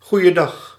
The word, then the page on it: goeiedag: /ɣu.jə.ˈdɑx/